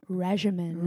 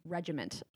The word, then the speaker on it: regimen: You're like, regimen. – Regimen. – It's a hard G and a hard T. It's regiment. – No, it's no T.